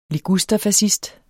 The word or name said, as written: ligusterfascist